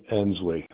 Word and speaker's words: Ensley: David Ensley. Last name is spelled E-N-S-L-E-Y.